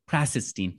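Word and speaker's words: Krassenstein: The Krasenstein brothers? – Krasenstein. – Thank you.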